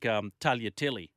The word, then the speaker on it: tagliatelle: Annoys me as much as sounding the G in Italian, where it's meant to be silent. Yeah, like Tagliatelle, for example.